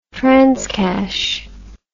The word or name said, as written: transcash